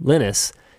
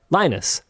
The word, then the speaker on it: Linus: The reason that I pronounce Linus Torvald's name Linus is because he is Finnish. – Yeah. – And no, I get that. Yeah. – In Finland, that's how that name is pronounced. Here in English, those letters in that order are not pronounced like that. They are Linus.